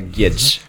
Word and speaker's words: Guedes: Ska vi inte ta portugisiskt uttal på den? Gonzalo Guedes. Ja, Gedge. – Gunjar Gedge.